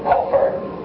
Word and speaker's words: kippur: This word atonement is kippur, or some Jews pronounce it kofar, the verb, to cover.